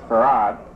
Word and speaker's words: Fard: It's spelled in the encyclopedia F-A-R-D, but it's pronounced Farad.